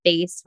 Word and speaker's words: PHACE: It is associated with large facial hemangiomas, and face is spelled P-H-A-C-E,